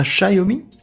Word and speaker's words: Xiaomi: On prononce ça Xiaomi Xiaomi, ouais.